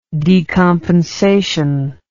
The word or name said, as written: decompensatory